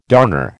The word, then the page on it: darner: American English